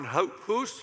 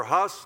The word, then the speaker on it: Hus: We talked about John Wycliffe and John Huff, Jan Hus or Huss, depending on how you say it.